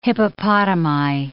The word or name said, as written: hippopotami